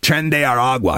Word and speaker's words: Tren de Aragua: Tren de Aragua. I'm finally getting the pronunciation of that right.